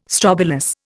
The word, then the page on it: strobilus: Indian